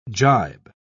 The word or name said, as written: jibe